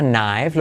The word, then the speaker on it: knife: Knife. Lo mismo es acá no se pronuncia, es ahí se dice I.